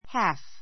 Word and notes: half: hǽf; ハふ; hɑ́ːf; ハーふ

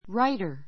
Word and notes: writer: ráitər; ライタ

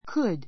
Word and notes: could: kəd; kúd; クド

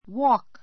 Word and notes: walk: wɔ́ːk; ウォーク

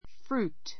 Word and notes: fruit: frúːt; ふルート

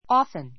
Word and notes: often: ɔ́ːfn; オーふン; ɔ́fn; オふン

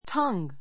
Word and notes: tongue: tʌ́ŋ; タンぐ

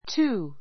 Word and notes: too: túː; トゥー

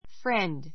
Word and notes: friend: frénd; ふレンド

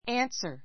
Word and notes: answer: ǽnsər; アンサ; ɑ́ːnsə; アーンサ

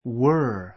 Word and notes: were: 弱形 wər; ワ～; 強形 wə́ːr

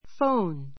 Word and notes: phone: fóun; ふォウン

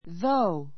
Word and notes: though: ðóu; ぞウ

